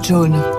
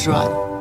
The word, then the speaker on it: Joan: Je m'appelle John, John Vera. Ça s'écrit J-O-A-N. Mais j'ai horreur qu'on dise Joanne.